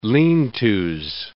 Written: lean-tos

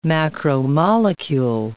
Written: macromolecule

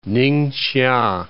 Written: Ninghsia

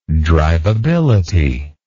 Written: drivability

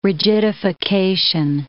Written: rigidification

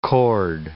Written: chord

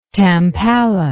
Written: tampala

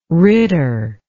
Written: ritter

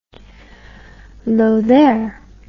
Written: lothair

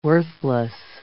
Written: worthless

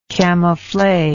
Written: camouflet

